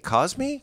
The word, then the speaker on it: Cosme: Yeah, but, and we had I can't pronounce this right. C-O-S-M-E. Cosme? – I would think, yeah. – I haven't heard anybody talk about it. – I don't know how else you would pronounce that. – Cosme, yes.